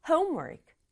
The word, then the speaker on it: homework: 앞부분 강조해서 homework. Homework 이렇게 발음하죠.